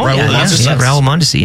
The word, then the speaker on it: Mondesi: Mondesi. – I don't know if I was pronouncing that correctly. – Him? Oh, Raul Mondesi, yeah. – That's how it is.